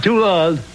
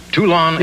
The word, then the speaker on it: Toulon: Did I pronounce the town right? Is it Toulon? – Toulon. – Toulon, yes. Illinois.